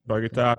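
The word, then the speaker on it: Bogotá: Bogota.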